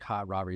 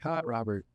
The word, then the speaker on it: Hot Robert: They call me hot Robert. – Hot. – But you don't just say it like hot Robert. It's like kind of like hot pocket. You're like hot Robert.